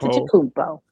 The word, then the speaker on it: Antetokounmpo: Giannis Antetokounmpo. Say it again. Antetokounmpo. – Antetokounmpo.